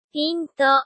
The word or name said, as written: pinto